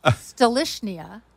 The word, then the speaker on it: Stolichnaya: No, I had a waitress on a cruise line who was from Russia, and I said, how do you say Stoli? I mean, how do you say that appropriately? She goes, it is Stolichnaya.